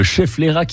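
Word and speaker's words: Schefflera: Bon, au moins s'il pleut, on est à l'abri avec le chef Lera. – Vous l'avez bien prononcé, le chef Lera qui est une plante d'intérieur et on l'appelle la plante parapluie.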